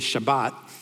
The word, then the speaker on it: Shabbat: You see, the Hebrew word in Sabbath is Shabbat. It's how it's pronounced.